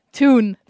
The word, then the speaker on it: Thun: Thun. That's where I was born. – That's T-H-U-N, but pronounced Thun.